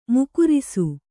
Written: ಮೂಗೇರಿಸು

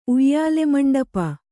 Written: ಉಯ್ಯಾಲೆಮಂಡಪ

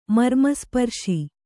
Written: ಮರ್ಮಸ್ಪರ್ಶಿ

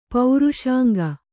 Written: ಪುರುಷಾಂಗ

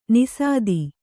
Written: ನಿಸಾದಿ

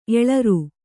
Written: ಎಳರು

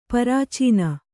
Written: ಪ್ರಾಚೀನೆ

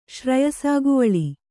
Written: ಶ್ರಯಸಾಗುವಳಿ

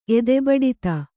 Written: ಎದೆಬಡಿತ

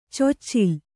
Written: ಚೊಚ್ಚಿಲು

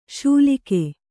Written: ಶೂಲಿಗೆ

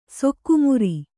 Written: ಸೊಕ್ಕುಮುರಿ

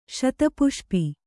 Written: ಶತಪುಷ್ಪೆ

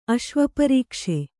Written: ಅಶ್ವಪರೀಕ್ಷೆ

